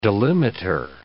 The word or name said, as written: delimiter